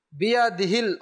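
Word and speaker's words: বিয়াদিহিল (بيده): বিয়াদিহিল খৈর, বিয়াদিহিল খৈর, বিয়াদিহিল খৈর, বিয়াদিল মানে কি? বিয়াদিল অনেক সময় হা উচ্চারণই করে না, অভ্যাস না করার কারণে, খেয়াল না করার কারণে।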